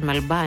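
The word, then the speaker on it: Melba: Η Μελμπά είναι, δεν ξέρω αν το έχω πει λάθος, το προφέρω λάθος, Μελμπά είναι το σωστό, ναι.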